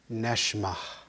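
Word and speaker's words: Neshma (נשמה): If you say it right, and I don't always pronounce it right in the Hebrew, what's it sound like? Neshma. Almost like you're taking a breath, doesn't it? Neshma. It's got the sound of breath.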